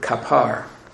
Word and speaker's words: kapar: The expression Yom Kippur comes from the Hebrew root word kapar. K-A-P-A-R is the way we would spell it.